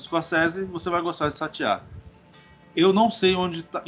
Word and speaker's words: Satya: Satya, é isso mesmo. Satya, confia.